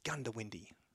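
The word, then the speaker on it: Goondiwindi: The Gundawindi is pronounced Gundawindi.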